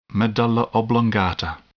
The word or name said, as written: medulla oblongata